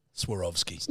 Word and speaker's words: Swarovski: It's Swarovski. – Now because you're saying that, I know how to pronounce it. Swarovski.